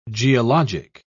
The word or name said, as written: geologic